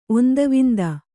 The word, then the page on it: ಒಂದವಿಂದ: ondavinda